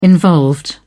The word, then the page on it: involved: British English